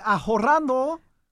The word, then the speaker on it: ahorrando: Estoy ahorrando. – Ahorrando. The H is silent in Espanol.